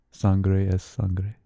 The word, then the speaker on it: sangre es sangre: Or sangre es sangre.